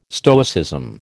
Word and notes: Stoicism: American English

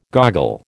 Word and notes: goggle: American English

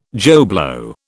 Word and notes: Joe Blow: British English

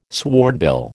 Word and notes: swordbill: American English

